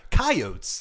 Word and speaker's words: Coyotes: Coyotes. You got to say it like you're an old prospector.